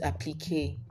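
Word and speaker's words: appliqué: It's also a French word, so it's pronounced applique.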